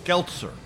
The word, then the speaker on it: Geltzer: Last name rhymes with Geltzer, Josh Geltzer, right? – That's right.